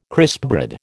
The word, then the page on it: crispbread: American English